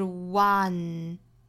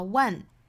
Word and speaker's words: once: 注意前鼻音发音呢是要求我们舌尖抵上齿音，用鼻腔发声的，所以是 once, 不要读成了 one，也不要读成了 one，应该是 once.